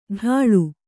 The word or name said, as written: ಡಾಳು